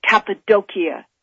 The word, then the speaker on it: Cappadocia: known as Cappadocia. That is the way it's pronounced in Turkey.